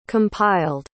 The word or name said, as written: compiled